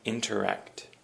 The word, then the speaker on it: interact: And the next word is interact. Interact. And of course, just as interaction is a noun, interact is a verb. And interact also has the emphasis on the ak. Interact.